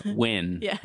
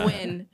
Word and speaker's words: Nguyen: No, it's not the pronunciation of Nguyen or Win. – Win. – Yeah. – How to pronounce Win, which is a common Vietnamese last name. – Maybe. – Yeah. I'm not sure. But it's like spelled like N-G-Y-U-E-N, I think, or something close to that.